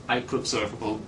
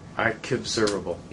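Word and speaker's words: IQbservable: We didn't want to write a long thing, so we say iQobservable or iQueryable observable pronounced is the interface name. – iQobservable.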